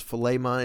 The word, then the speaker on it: Philemon: The second reading is a reading from the letter of Paul to Philemon, sometimes pronounced Philemon. It's pronounced different ways depending on your preference.